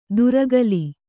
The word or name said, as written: ಧುರಗಲಿ